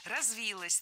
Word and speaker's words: развилось: Развилось тоже не лучший вариант. Более предпочтительным считается вариант с ударением на окончании. Развилось.